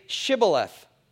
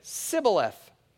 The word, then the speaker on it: Shibboleth: And if he said no, then they would say to him, 'Then say Shibboleth.' And he said, 'Sibboleth,' for he could not pronounce it right.